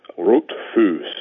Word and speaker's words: Rothfuss: Rothfuss is actually an old German name. It would have been pronounced Rotfuss.